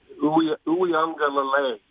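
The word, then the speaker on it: Uiagalelei: Uyunglele is how you pronounce it, supposedly.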